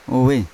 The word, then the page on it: ove: Yawa